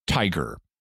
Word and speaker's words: TIGHAR: One is a group called the International Group for Historical Aircraft Recovery, T-I-G-H-A-R, pronounced Tiger.